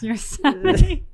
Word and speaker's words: Yosemite: You just learned it's Yosemite. – No, I'm... – Tucker taught him. – All right, guys. – Tucker's like, no, Dad, this is how you say it.